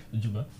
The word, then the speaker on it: Djouba: Indice, D-J-U. Donc, la première syllabe, c'est Jou. – Junabi. – Non. La deuxième syllabe n'a que 2 lettres. Et ce sont les 2 premières lettres de l'alphabet. Pardon ? – Djouba.